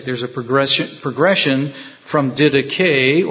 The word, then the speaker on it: didache: So there's the second word is didache. Or didache, D-I-D-A-C-H-E. It looks like did ache, but it's pronounced didache, or didache. And that particular word is in Greek the act of teaching.